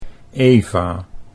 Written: Eva

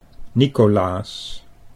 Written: Nicolaas

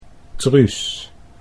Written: Truus

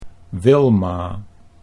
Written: Wilma

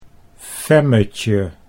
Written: Femmetje

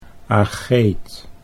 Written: Ageeth

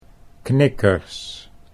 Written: knikkers

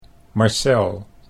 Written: Marcel

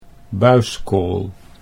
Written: Buiskool

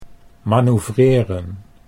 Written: manoeuvreren

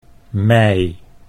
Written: mei

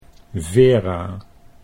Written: Vera

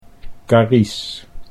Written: Carice

